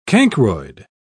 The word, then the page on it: cancroid: British English